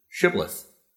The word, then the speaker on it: shibboleth: The sentries would ask each person who was crossing the river to say the word shibboleth. The Ephraimites would say, who had to say shh in their language, pronounce the word with an S, and thereby were unmasked as the enemy, and they were slaughtered.